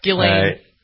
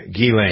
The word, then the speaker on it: Ghislaine: Ghislaine. – Ghislaine. – Ghislaine Maxwell. – The S is silent. The H is silent. And it's like G-E-E-L-A-I-N-E. That's how you should pronounce it.